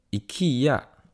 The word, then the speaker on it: IKEA: 那今天其实我刚从应该是瑞典，或是说全世界最大的 IKEA 回来。对呢，那我在，因为我们那个，但是因为这一间 IKEA 其实是在距离市中心要搭车一个小时左右的地方，是在一个郊区。然后我也因此得知怎么把 IKEA 念成正确的瑞典文。那 IKEA 的正确念法是叫做 IKEA，没错，这个是我从他们的公车广播得知，因为他们的其中一个站牌的名字就叫做 IKEA，我们就在那边下车，然后进行了我们的采购之旅。